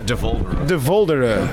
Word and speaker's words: De Voldere: De Voldere. – Oh, okay. – It's almost like Voldemort. De Voldemort.